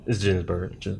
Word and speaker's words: Ginsburg: It's Ginsburg.